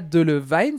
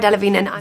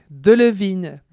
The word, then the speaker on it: Delevingne: C'est pas Delevingne, c'est pas de la vigne. On l'écoute elle-même nous dire comment ça se prononce. – Hi, I'm Cara Delevingne. – Voilà, c'est donc Delevingne.